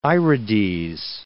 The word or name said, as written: irides